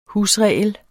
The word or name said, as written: husregel